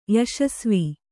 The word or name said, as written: ಯಶಸ್ವಿ